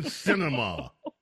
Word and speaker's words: Sinema: First three words of that last name, sin. Kirsten Sinema.